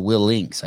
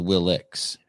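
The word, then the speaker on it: Willink: Oh, it's Will Links. I thought it was Will Licks.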